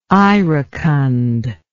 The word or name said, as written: iracund